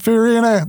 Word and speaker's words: Fiorina: Fiorina.